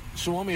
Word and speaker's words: Suomi: Suomi is how you say Finland in Finnish.